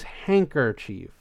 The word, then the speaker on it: handkerchief: But I've never heard anyone ever pronounce the D. It's always handkerchief to me.